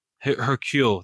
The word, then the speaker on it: Hercule: Haru Perot? – Yeah. – However you say his name. – Hercule. – Hercule, thank you, Perot.